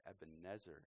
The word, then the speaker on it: Ebenezer: Although I would just make a side note here that if you sing the lyric, the way it was pronounced in Hebrew, Ebenezer, it rhymes nicely with pleasure. When you sing Ebenezer, it doesn't rhyme with pleasure.